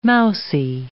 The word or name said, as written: mousy